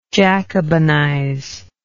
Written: Jacobinize

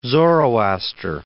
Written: Zoroaster

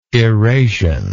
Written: erasion

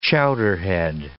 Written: chowderhead

